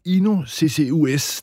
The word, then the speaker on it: INU CCUS: Carina Søgaard, du er direktør for INU CCUS. Det er lidt svært at udtale, men hvad er I sat i verden for at løse? – Ja, du udtaler det helt rigtigt, vil jeg sige.